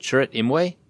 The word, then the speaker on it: Chirrut Imwe: Chirrut Imwe? – Yep.